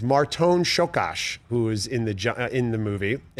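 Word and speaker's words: Csokas: It's Martone Shokash. Learn how to pronounce it. And I was like, I will learn how to pronounce this. Martone Shokash.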